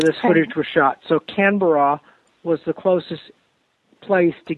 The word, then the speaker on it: Canberra: I stood a good chunk of the time in Sydney, and then I went to, I'm probably saying it wrong, Canberra, where Canberra.